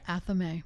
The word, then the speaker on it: athame: And that leads me to, I can never say it right, athime? So again, here we go with like pronunciation of words. So what do you say? – Athame. – Athame. She puts the emphasis on the athame. I say athame.